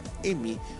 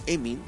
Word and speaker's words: Emin: Y el punto número 5 tenemos a Emi, o Emin se pronuncia, que se refiere al espíritu o al alma de ese espíritu.